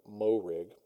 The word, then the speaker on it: Moehrig: Moving to the Raiders, I'm going to go with Trevon Moehrig. Is that how you pronounce his last name? – Mm-hmm.